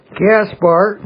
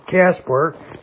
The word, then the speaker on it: Gaspar: Gaspar, G-A-S-P-A-R, or some people pronounce it Caspar, C-A-S-P-A-R. Second name is Melchior, if I'm pronouncing it right, M-E-L-C-H-I-O-R.